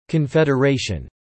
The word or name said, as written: confederation